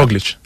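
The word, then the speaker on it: Roglič: Mira, ya que te tengo, Roglic, tampoco se pronuncia Roglic, ¿cómo lo tenemos que pronunciar? – Roglic. – Roglic, ah, sí, bien. Este sí.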